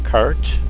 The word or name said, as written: cart